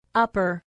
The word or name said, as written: upper